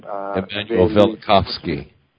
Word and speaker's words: Velikovsky: Emmanuel Velikovsky.